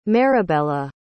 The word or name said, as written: maribella